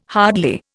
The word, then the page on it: hardly: Indian